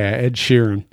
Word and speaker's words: Ed Sheeran: Yeah, Ed Sheeran.